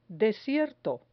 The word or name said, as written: desierto